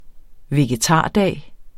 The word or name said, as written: vegetardag